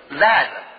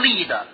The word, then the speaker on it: led: 这些同学们，这是我们跑步的助跑的，就是请看一下下面一个词 led，不能读成 lead。